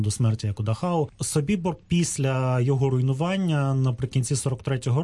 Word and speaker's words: Собібор: У випадку Собібора я наголошую, що я вживаю такий наголос через те, що цей табір розташовувався на території Польщі, і поряд із ним знаходиться польське село Собібор. яке за правилами польської мови має наголос саме на голосний «і».